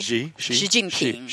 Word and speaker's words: Xi Jinping: Getting it right? G? Chairman G? Is that how you pronounce his name? – X-U. – X-I. – What's the name? – Xi. – Xi Jinping. – Xi Jinping, yeah.